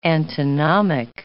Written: antinomic

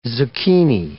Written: zucchini

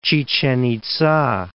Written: Chichen Itza

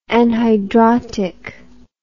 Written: anhidrotic

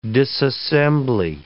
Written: disassembly

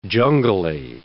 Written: jungly

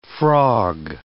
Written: frog

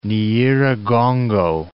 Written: Nyiragongo

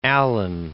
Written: Allen

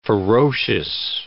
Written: ferocious

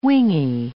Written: wingy

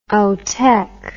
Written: outtech

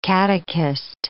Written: catechist

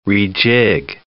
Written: rejig

